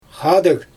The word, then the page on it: xaadɯg: Xyzyl